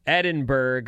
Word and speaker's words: Edinburgh: Edinburgh makes the list. American news anchors were criticized for mispronouncing it during the coverage of the Queen Elizabeth's memorial. It's Edinburgh.